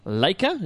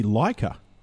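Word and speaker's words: Laika: Now, it's produced by Leica. Is that how I'm pronouncing that right there? – I think it might be Leica. – Is it? No, I think L-A-I-K-A. I'm going to go Leica.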